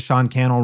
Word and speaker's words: Sean Cannell: And then my name is Sean Cannell, rhymes with YouTube channel.